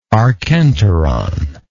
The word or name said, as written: archenteric